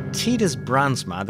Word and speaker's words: Titus Brandsma: Now, Titus Brandsma, that's how you pronounce it more or less in Dutch. I think you would say Titus in English.